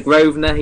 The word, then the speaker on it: Grosvenor: It's a silent S. – It's the Grosvenor. He calls it the Grosvenor, which is hilarious.